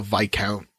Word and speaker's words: viscount: Oh, that's how that's pronounced. Yes. I've only seen that word written down. – Viscount? – I've always thought there was that. Wow. – Oh, yes. Like, never mind. It's like vis-a-vis. Nope.